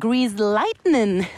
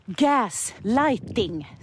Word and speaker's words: gaslighting: Jag blandar ihop det med grease lightning. Det är gaslighting, så säger man.